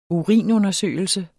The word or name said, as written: urinundersøgelse